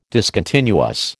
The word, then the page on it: discontinuous: American English